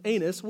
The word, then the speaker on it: Annas: On the next day, their rulers and their elders and their scribes gathered together in Jerusalem with Annas, which is a really unfortunate name, but that is how you pronounce it, the high priest and Caiaphas and John and Alexander and all who were the high priestly family.